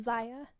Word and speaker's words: Zaya: What they did, is it Zaya Wade? Is that how you say it? Dwayne Wade and Gabrielle Union's daughter? – Oh, yeah, I think so. – Zaya. – How is it spelled? – Z-A-Y-A. – Yeah.